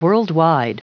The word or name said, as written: worldwide